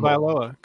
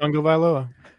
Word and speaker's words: Tagovailoa: Tagovailoa. Come on. – Tagovailoa. Is that how you say it? – Tagovailoa. – All right. Tagovailoa.